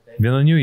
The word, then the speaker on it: Villeneuve: And it's been remade by Denis Villeneuve, however you say his name. Villeneuve, yeah.